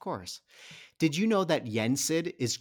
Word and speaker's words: Yensid: Yensid.